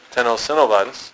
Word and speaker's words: tenosynovitis: Tenosynovitis.